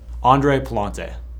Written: Andre Pallante

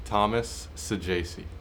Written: Thomas Saggese

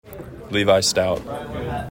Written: Levi Stoudt

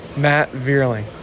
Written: Matt Vierling

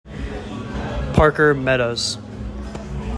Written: Parker Meadows

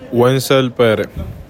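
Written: Wenceel Pérez

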